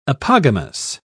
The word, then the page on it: apogamous: British English